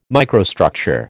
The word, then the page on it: microstructure: American English